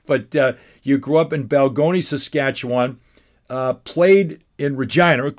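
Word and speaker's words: Balgonie: Is it Balogne? – It's Balgoni. – Balgoni. I'm sorry. Balgoni, Saskatchewan.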